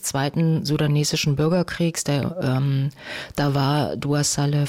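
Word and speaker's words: Dua Saleh: Dua Salle wird sie ausgesprochen.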